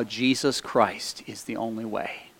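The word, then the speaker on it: Jesús: I was sitting across from a guy whose name is spelled J-E-S-U-S. Now, he's Spanish, so it's pronounced Jesus, but his name is Jesus.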